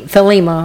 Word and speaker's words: Thelema: It was here that Crowley's philosophy of Thelma, T-H-E-L-E, Thelema, I'm sorry, I'm saying it wrong, which sounds like those thetas, but, excuse me, I knew that was going to happen.